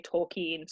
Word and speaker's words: Tolkien: So there's a lot of back and forth about whether it's Tolkien or Tolkien. The British say Tolkien, so I'm going with that.